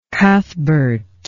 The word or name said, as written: cuthbert